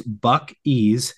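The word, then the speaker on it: Buc-ee's: And his text back was Bucky's, pronounced Buck-E's.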